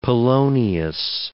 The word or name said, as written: Polonius